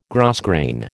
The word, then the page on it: grosgrain: American English